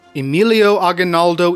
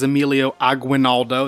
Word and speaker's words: Emilio Aguinaldo: Emilio Aguinaldo y Fami, often mispronounced as Emilio Aguinaldo, that's the American pronunciation, it's Emilio Aguinaldo, would be the face of Filipino resistance to both the Spanish and the Americans.